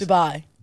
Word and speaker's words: Dubai: Dubai. – Dubai. Dubai. – It's not J-U-B-A-I. Dubai. – Yeah, but... – It's Dubai.